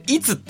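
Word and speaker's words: 釔: これはですね、いつって読むんですね。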